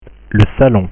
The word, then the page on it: le salon: French